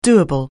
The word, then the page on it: doable: anglais